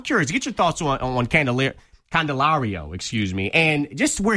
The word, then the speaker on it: Candelario: Candelario. – Thank you. Thank you, Casey. Candelario, 23-year-old.